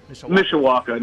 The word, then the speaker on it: Mishawaka: Is your town, is it Mishawaka? Is that how you say it? – Mishawaka.